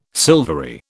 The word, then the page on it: silvery: British English